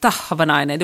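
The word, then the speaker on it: Tahvanainen: Jag ska intervjua Sanna, nu får jag kämpa här med uttalet igen då, men Tavanainen, var det rätt? – Tavanainen, du måste ha ett starkt H.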